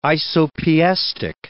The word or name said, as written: isopiestic